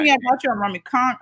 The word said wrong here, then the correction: tonk: I don't know nothing about that. – Okay, tonk. – Tonk. Yeah. See, look at that. I'm gonna say it right.